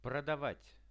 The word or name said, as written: prodavat